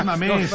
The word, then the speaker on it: Mamés: O el otro es no mames. – Mamés. – Un acento. Pronuncia correcto.